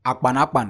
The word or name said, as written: akpanakpan